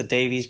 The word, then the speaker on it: Davies: In America, they're known as the Davies Brothers, because we pronounce the E, and Dave likes to pronounce the E and Ray doesn't.